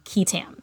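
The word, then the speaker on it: qui tam: And the sort of more formal or legal term for whistleblower suits is what's called a QUITAM action, and that's spelled Q-U-I space T-A-M, QUITAM.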